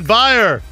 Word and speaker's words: Beyer: Oh, Dan Beyer rhymes with Oscar Mayer.